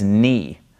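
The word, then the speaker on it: nu: This is Ni, Ni. So you got to get used to pronouncing this as an N, Ni.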